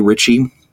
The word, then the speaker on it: Ritchie: Ritchie. Last name is spelled R-I-T-C-H-I-E.